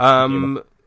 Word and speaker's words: Numa: is it Numa? Is that how it's Numa. – pronounced? – Yep, Numa.